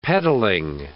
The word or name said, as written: pedalling